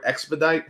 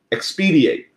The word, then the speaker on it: expedite: But like the word expedite. Hey, we need to expedite this. – Yes. – I've been saying this till 27. Expediate. I've been pronouncing expediate, and no one said anything.